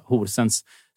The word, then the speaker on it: Horsens: Eh, vi kör över Mjällby med 4-1 eh, och vi är verkligen med och tävlar med eh, Horsens.